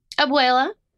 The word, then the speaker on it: abuela: Abuela.